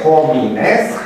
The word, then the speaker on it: homines: Pluralul este homines, H inițial însă nu se pronunța,